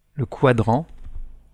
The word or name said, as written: quadrant